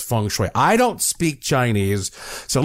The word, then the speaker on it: feng shui: it's spelled F-E-N-G-S-H-U-I. Some people call it feng shui. I've heard all kinds of pronunciations, but I have done my best to research what the old ancient Chinese masters have said, and it is called feng shui.